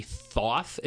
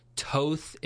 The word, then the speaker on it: Thoth: It could be Thoth. It's spelled T-H-O-T-H. It could be Thoth. It could be Toth. It could be Thoth. It could be Taut. There's a lot of different pronunciations.